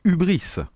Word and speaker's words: hubris: H-U-B-R-I-S. Syndrome du Hubris.